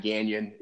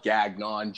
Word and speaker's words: Gagnon: It's where I built probably over 70% of my business, but you can look me up there, under my name, Matt Gagnon. It's spelled Gagnon, G-A-G-N-O-N.